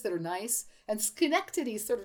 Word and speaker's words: Schenectady: I'm going to spell it. This is, again, in New York. S-C-H-E-N-E-C-T-A-D-Y. Let me see if I can wrap my mouth around this. Schenectady? – Well Correct. Done.